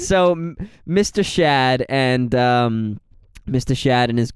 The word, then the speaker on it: Shad: Boyfriend's name is Shad. – Like Shad? – Like Chad, but with an S. – Is that... That's how it's spelled? – Yes, that is S-H-A-D, Shad.